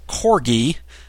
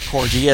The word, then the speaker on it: Corgi: And also Ed from Australia did check in and let us know that it is Corgi, not Corgi, as I mispronounced it last week, and he offered up, hey, maybe you guys can start a rumor about a Wii, Nintendo Wii lightsaber game.